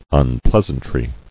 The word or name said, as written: Unpleasantries